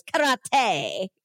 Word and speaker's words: karate: And then as soon as he says karate, he goes karate every single time. – Well, that is the correct way to pronounce it with Japanese accent.